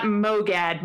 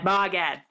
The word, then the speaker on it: MOGAD: So not MOGAD, MOGAD. We're going to do MOGAD.